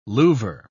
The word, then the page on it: louver: British English